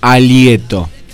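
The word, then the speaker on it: halieto: Y a su vez tenemos pregunta en el día de hoy, en este miércoles, y arrancamos diciendo, ¿qué significa la palabra alieto? Alieto, que se escribe con H, H-A-L-I-E. H-A-L-I-E-T-O, alieto.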